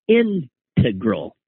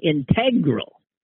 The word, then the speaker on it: integral: He says, I've always pronounced integral with the emphasis on the first syllable, integral. But I keep hearing people on podcasts emphasizing the second syllable, and it's driving me crazy.